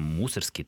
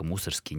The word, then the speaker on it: Мусоргский: Если Ирина обращалась к замечательному словарю Флоренса Людвига Гейнка «Имена, собственно, в русском языке», и там «Мусоргский», только «Мусоргский», никаких других вариантов быть не может. – Ну, это вообще довольно старая история. Есть сторонники той теории, что правильно произносить фамилию Мусоргский.